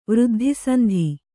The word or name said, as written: ವೃದ್ಧಿಸಂಧಿ